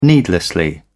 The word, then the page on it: needlessly: British English